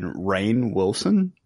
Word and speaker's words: Rainn Wilson: It stars Jason Statham, Ruby Rose, and Rainn Wilson. I never know if I'm saying his name right, but he's Dwight from The Office. – And you got it right.